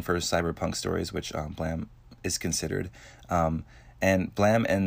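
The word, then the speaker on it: Blame!: It's pronounced Blam, but it's spelled Blame, and it has an exclamation point at the end.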